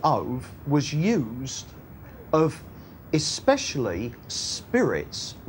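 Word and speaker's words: ov (אוב): ov. It's spelled O-B-E when you transliterate it into English, but it's pronounced ov.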